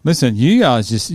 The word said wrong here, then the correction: Yuva: You're the one saying his name wrong, so... – It's a V. – Yuva. Yeah. I can say it.